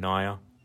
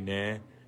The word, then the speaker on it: Naya: So I've used that name Naya because it kind of rhymes with fire.